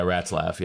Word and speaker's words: Ratzlaff: Like, we had, I think that's how you pronounce his last name. – Yeah, Ratzlaff. Yeah.